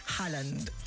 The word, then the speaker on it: Haaland: Haaland se pronuncia. Haaland.